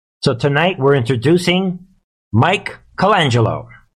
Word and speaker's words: Colangelo: His name is Matt Colangelo, is I think how I pronounce his last name. It's C-O-L-A-N-G-E-L-O, Colangelo.